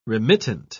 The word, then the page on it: remittent: British English